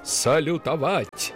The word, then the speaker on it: салютовать: И норма произношения закрепилась именно в варианте Левитана «салютовать». А позже Юрию Борисовичу так часто приходилось произносить это слово в новой норме с ударением на последний слог, что коллеги в шутку прозвали Левитана «салютан».